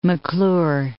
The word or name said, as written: M'Clure Strait